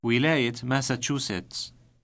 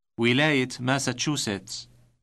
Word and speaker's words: ولاية: ولاية أوهايو ولاية أوهايو Have you noticed the T sound inserted at the end of the word for state? It is always inserted when a pronoun or a noun follows the word.